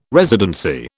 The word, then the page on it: residency: British English